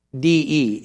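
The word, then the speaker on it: de (δέ): In John 3:1, there is a little Greek conjunction that precedes there was a man of the Pharisees, and it is D-E in our English way of spelling that Greek word, pronounced de, and it would be translated but, and is the same word as in chapter verse 24.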